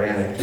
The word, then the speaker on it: Alnwick: Don't you love it that word is spelled, it's pronounced Alnwick. It has, or Annick, yeah.